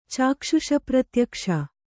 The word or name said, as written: ಚಾಕ್ಷುಷಪ್ರತ್ಯಕ್ಷ